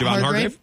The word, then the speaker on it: Hargrave: Hargrave? – Yep.